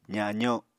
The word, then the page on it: zazo: apatani